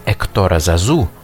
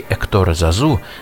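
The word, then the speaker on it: Hector Zazou: Ключ к музыкальной вселенной Эктора Зазу — я все-таки на французский манер буду произносить его имя, хотя не все считают это правильным. Так вот, ключ к вселенной Эктора Зазу — это эмоциональность, откровенная чувственность, заложенная в основу каждого проекта.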